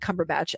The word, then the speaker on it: Cumberbatch: I knew that I wanted to see the film because I enjoy watching Benedict Cumberbatch act.